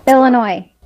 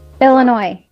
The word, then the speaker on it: Illinois: Illinois. – Vamos a poner la parte corta. – Illinois.